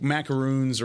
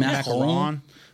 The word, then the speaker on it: macaron: Macaron. That's how you say it. – What? – Macaron. – Okay. – That's how you say it in French.